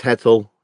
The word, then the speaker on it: Hetzel: It's Hetzel is how Americans, we all pronounce it. Technically, it would have an umlaut over the O and there wouldn't be an E.